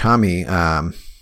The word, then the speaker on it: Homme: Yeah, and I don't know if we mentioned this at all, but Josh Homme, did I say that right? – Yes, Josh Homme.